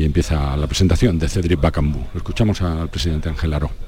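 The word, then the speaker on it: Bakambu: Bacambú, ya te lo puedo además eh, eh, confirmar, como si fuera con acento en la U, Cedric Bacambú, que se ha sentado en primera fila y comienza el acto, eh, el DIRCON del Real Betis Balompié.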